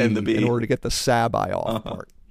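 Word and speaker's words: C'baoth: And Zahn said that he pronounced it Sabaoth.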